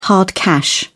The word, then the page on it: hard cash: British English